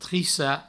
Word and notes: trissar: trissa

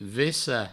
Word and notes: vessar: vessa

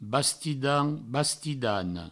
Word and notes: bastidan, bastidana: Provençal; bastidan, bastidano

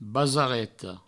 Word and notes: basareta: Provençal; basareto

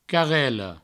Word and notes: carrela: provençal; carrello

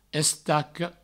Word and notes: estaca: provençal; estaco

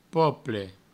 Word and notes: pople: provençal

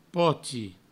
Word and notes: poti: provençal

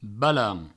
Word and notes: balanc: balans; Provençal

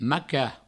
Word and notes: macar: provençal; maca

